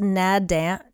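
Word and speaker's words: Naadam: I guess it's pronounced Nadam. It's N-A-A-D-A-M.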